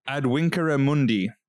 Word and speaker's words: Ad Vincere Mundi: Adwinkere Mundi, which... So for those who aren't classical dorks, it'll look like it's spelled Ad Vincere Mundi, which means a world to win in classical Latin.